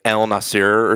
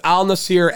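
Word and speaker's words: Al Nassr: It's like... like El Nasir or something, or along those lines. – I was going to say, I was going to butcher it too, so I didn't know if that's what it was. – I'll give it to you. It's close enough. It's Al Nasir FC.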